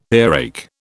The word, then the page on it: earache: British English